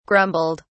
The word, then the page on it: grumbled: British English